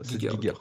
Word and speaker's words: Giger: On va dire que je suis pas connaisseur euh, de mm, des univers de de, Giger, c'est ça C'est comme ça que ça se prononce. – Giger. Allemand, Giger.